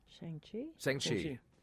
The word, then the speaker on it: Shang-Chi: David writes, Black Widow, season one of Barry, Shang-Chi. Am I saying that right? – Shang-Chi.